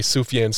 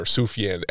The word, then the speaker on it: Sufjan: His name is pronounced Sufjan or Sufjan, S-O-O-F-Y-A-H-N. That would be the phonetic pronunciation. It is not Sufjan or anything like that.